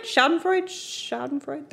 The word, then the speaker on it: schadenfreude: Did I say that right? Schadenfreude? That's right. – Sure. Sounds right to me.